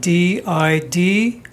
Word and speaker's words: DiDomizio: Yeah, the last name is spelled DiDomizio.